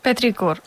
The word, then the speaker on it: Petricor: Que Petricor, com es pronuncia? Digue-ho tu. – Petricor. – Petricor, si ho diem, ja ho diem bé.